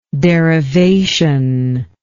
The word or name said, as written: derivationist